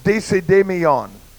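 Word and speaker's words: desidemion (δεισιδαιμονία): The word in Greek means, or is pronounced, desidemion. And it means to fear a demon.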